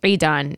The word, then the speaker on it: Edan: It's Edan. But you know what? It's the same thing. Because truly, it's pronounced Edan in Arabic. So when I'm saying it's Edan or Edan, that's just like the Anglo version of it, so it really doesn't matter.